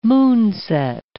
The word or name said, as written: moonset